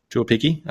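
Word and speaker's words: Tuaupiki: Mate, can you help me with the pronunciation of Tane? – Tuapiki. – Okay, very good. That was a lot easier than what it looks.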